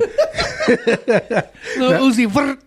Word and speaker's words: Lil Uzi Vert: Lil Uzi Vert.